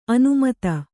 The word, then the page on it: ಅನಿಮಿತ್ತ: animitta